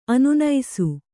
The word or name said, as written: ಅನಾನಸ